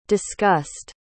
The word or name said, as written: discussed